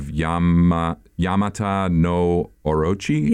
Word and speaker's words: Yamata no Orochi: Yamata no Orochi.